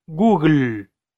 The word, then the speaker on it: Google: ¿Cuál es la forma correcta de pronunciar Google en inglés? Google. Como si escribieras G-U-G-L en español. Google.